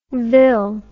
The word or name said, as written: ville